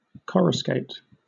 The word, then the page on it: coruscate: British English